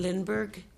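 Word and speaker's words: Lindberg: Lindbergh. – Lindbergh. Okay. Thank you. B-E-R-G or B-U-R-G? – E-R-G. – E-R-G.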